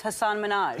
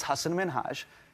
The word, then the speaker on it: Hasan Minhaj: Yeah, but the real way you pronounce it, and this is a big deal because my parents are here, it's Hasan Minhaj. And people always mispronounce it. They're always like, Hasan Minhaj, Hussein.